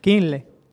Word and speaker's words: Kindle: Kindle.